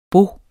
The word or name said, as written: baud